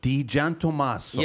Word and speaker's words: DiGiantommaso: DiGiantommaso. – Yeah. In English, it's English in the front and Italian in the back.